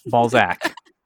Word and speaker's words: Balzac: Did I say that right? – It's Balzac. – Balzac.